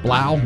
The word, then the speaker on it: Blough: What do you say his name? B-L-O-U-G-H, Blow? David Blau? David Blau, quarterback with the Detroit Lions.